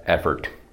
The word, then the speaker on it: effort: Tohle nahoře je afford. Přízvuk na druhé slabice dlouhé O. Tohle má přízvuk na první slabice, nevím jak naznačit krátké O. Tohle je effort.